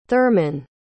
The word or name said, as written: therman